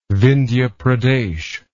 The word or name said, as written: Vindhya Pradesh